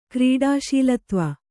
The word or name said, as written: ಕ್ರೀಡಾಶೀಲತ್ವ